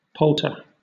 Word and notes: palter: British English

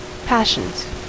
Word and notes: passions: American English

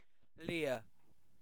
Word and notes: leer: British English

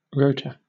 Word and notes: rota: British English

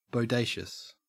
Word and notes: bodacious: Australian English

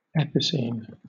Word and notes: epicene: British English

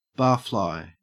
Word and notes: barfly: Australian English

